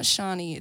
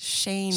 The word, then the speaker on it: Shani: My name's Shani. – There you go. – Pronounce Shani, okay? If you guys see it on the poster, it's not Shani, it's Shani.